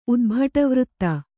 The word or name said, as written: ಉದ್ಭಟವೃತ್ತೆ